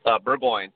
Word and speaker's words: Burgoyne: Burgoyne, two syllables.